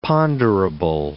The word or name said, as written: ponderable